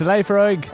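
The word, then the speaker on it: Laphroaig: Number one is, I'm probably going to butcher it, is the Lefroig. – Lefroig. – Lefroig. – It's Lefroig.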